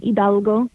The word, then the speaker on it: Hidalgo: But the last name is Spanish, so the H is silent. So Hidalgo. – Ildago. – H-I-D-A-L-G-O.